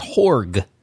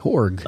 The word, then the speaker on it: Jorge: It's pronounced Horg. J-O-R-G-E. Horg.